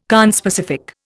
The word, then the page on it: conspecific: Indian